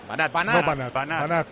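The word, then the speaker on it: Vanat: Para no coger vicios en la pronunciación, la pronunciación del delantero centro del Girona ahora es Banat. – Banat. – Es Vladislav Andrejevic Banat. – O sea, aguda, ¿no? – Andrejevic.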